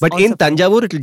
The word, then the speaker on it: Tanjavur: Tanjore is the British pronunciation of Tanjavur.